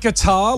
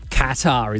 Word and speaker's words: Qatar: Is it guitar? Like guitar. – Qatar. – No. – Qatar, isn't it? – Correct. – Oh, yeah. Qatar. Okay. – Which we mostly only know that because of Qatar Airways.